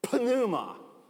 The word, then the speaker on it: pneuma: You know, it's where we get the word pneumatic, except we don't say pneuma. It's a silent P. The Greeks pronounce the P, okay? Pneuma.